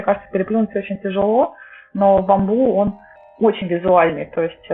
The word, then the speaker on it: Bamboo: Еще один продукт, который называется Bamboo. Правильно я произношу? Потому что его по-разному. – Да, Bamboo.